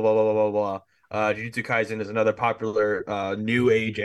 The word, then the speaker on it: Jujutsu Kaisen: Okay, speaking of anime, Sandwich, our resident anime expert, Jujutsu Kaisen season 2? – Yes.